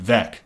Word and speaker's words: Veeck: Most of the old guard viewed him as a pariah after exposing most of their peers in his 1961 book, Veeck, as in Wreck, which is why I know how to correctly say his name. Because it's with two E's. So you'd think it'd be Veeck. – Oh, it's V-E-E-C-K? – Veeck.